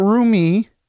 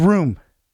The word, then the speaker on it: Roome: And not Rumi. Don't try and pronounce it that way. Don't be silly and pronounce it that way. It's not Ruma. No. Come on. It's Room. Guys, it's Room.